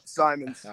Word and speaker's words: Symonds: I said that right, right, James? Yep, Symonds.